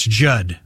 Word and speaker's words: Judd: That's Judd with two D's. The second D is silent.